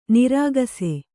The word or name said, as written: ನೊರಕಿಸು